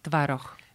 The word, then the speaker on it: tvaroh: Tvaroh.